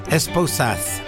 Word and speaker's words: esposas: In Spanish, the word esposa, I think it's how you say it. Esposas, which is spelled E-S-P-O-S-A-S.